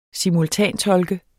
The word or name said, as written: simultantolk